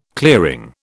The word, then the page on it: clearing: British English